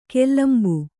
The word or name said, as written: ಕಲಂಬು